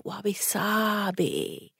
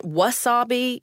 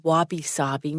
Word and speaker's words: wabi-sabi: And she said, no, she chuckled, wabi-sabi. And she and the grandfather took turns telling me what wabi-sabi means.